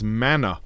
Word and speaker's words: manna: And that is the event of the mun, in English for some reason, pronounced as manna.